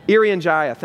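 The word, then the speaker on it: Irian Jaya: It was called From Jerusalem to Erinjira. Erinjira, I don't know if I'm saying that right. Erinjira? Erinjaya, thank you.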